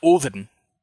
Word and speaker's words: Odin: Well, and actually, Othor or Othor is so we notice that the name Odin, which actually is pronounced Oven. The D is actually supposed to be like a hard TH, like you would have in the word the. And so it's Oven.